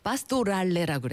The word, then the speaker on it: 파스토랄레: 음악에서 파스토랄레라고 그래요. – 마스토랄레. – 파스토랄레 네, 같은 발음.